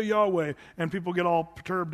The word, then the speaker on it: Yahweh: And so some people, usually it's the Hebrew scholars that pronounce it Yahweh, putting the YHWH, and then sort of what we would do is put vowels in there to try to make a speakable word.